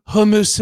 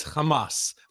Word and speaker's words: Hamas: We hate hummus and pita. And so, I mean, first of all, it's Hamas. He doesn't even know how to pronounce it. I mean, he's calling them hummus, Hezbollah, which is out of Lebanon, another terrorist organization.